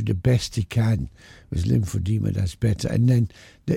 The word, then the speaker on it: Lymphoedema: Lymphoedema, is that what it's called? – Lymphoedema, yeah.